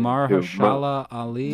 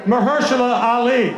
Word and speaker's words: Mahershala Ali: Mahershala Ali. – Two-time Academy Award winner, Mahershala Ali.